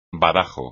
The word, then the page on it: badajo: espagnol